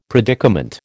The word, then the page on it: predicament: American English